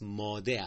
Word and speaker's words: ماده: یه ماده داریم، یه ماده، هم در نوشتنش و هم در تلفظش دقت بفرمایید که جنس مؤنث ماده است، ماده بدون تشدید، اما اون که گوهر هر چیز، جوهر و اساس هر چیز، ماده یعنی با تشدیده.